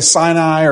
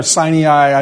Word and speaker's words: Sinai: Whether you say Sinai or Sinai, I don't really, I hear people pronounce it different ways, but yes.